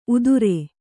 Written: ಉದುರೆ

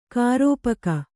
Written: ಖರಪಾಕ

